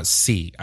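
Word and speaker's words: Skeie: The gentleman's name is Eli Lieb and Brandon C. I believe it's S-K-E-I-E, but the K is silent.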